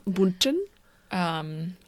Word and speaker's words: Bündchen: Giselle Bündchen. Is that how you say her name? – Yeah. Bündchen.